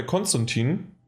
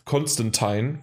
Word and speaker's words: Konstantin: Ich bin gerade bei Konstantin, äh, oder wie andere sagen, weil sie unbedingt das mal in Englisch aussprechen wollen, Constantine, was aber vollkommen falsch ist, weil es ist nämlich immer noch.